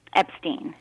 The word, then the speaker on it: Epstein: You pronounce it Epstein or Epstein? – Epstein. – Epstein. I just want to make sure.